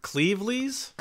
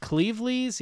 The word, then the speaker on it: Cleveleys: Um, they're uh, near Lancashire um, in a place called, am I pronouncing this right? Clevelis? Cle- Clevelis? – That's the town. I, I, see it as Cle- Cleveleys. Cleveleys, yeah.